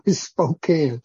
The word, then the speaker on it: Spokane: John Lake shut down all the hospitals in Spokane, or Spokane, I think the way they pronounce it. Thank you, Barry. I've been corrected by that more than once. Spokane.